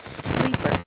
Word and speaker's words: Sleeper: It's spelled J-E-A, two N's, E-T-T-E, middle initial is P, and the last name is Sleeper. Sleep with the E-R.